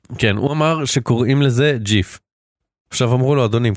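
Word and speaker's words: GIF: והנאום שלו הנאום שלו זה פשוט סרטון גיף. It's pronounced ג'יף.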